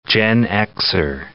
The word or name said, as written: Gen Xer